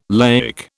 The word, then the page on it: laic: British English